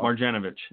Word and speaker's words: Marjanovic: Marjanovic. – You know who I'm talking about. – Marjanovic.